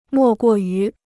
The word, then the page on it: 莫过于: mò guò yú